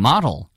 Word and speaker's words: model: 네, M-O-D-E-L 발음은요. 모델. 이렇게 당연히 뭐 나라에 따라서 들릴 수도 있겠지만 미국식 영어에서는 model. – 네, model. 자, 잘 안 되시는 분들은 말을 말어. 자, model.